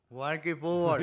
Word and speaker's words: Walking Football: A ver cómo pronuncias en inglés. Walking fútbol. – Walking Football.